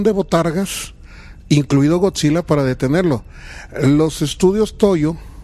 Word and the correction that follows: Toho: ¿Es Toyo o Toho? ¿Cómo se dice en español? – Toho. – Ay, los estudios Toho.